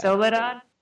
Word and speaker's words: Soledad: Soledad.